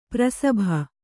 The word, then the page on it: ಪ್ರಸಭ: prasabha; kannada